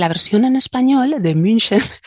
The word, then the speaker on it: München: El problema es cuando decimos Múnich, porque decimos, uy, esto es alemán, pues la CH será G, pero claro, no es Múnich en alemán, sino München.